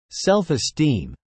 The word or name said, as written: self-esteem